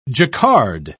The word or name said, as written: jacquard